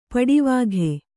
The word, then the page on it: ಪಡಿವಾಘೆ: paḍi vāghe